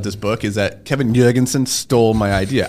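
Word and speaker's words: Jorgenson: That's Am I pronouncing this correctly? – That's very aggressive. It's either that or Eric Jorgensen. – Jorgensen.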